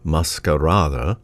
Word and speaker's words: Maskarade: The opera Masquerade, which is spelled in the Danish way, M-A-S-K-A-R-A-D-E, roughly Masquerada, the setting was at a masquerade ball, a masked ball.